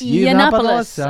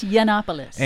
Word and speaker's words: Yiannopoulos: Iannopoulos? – Yiannopoulos. Right. I think it's Yiannopoulos.